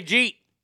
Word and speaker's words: jeet: See, here in the South, we say jeet. J-E-E-T. Jeet. And if you haven't, you said no Jew. You could say no, N-O, J-E-W.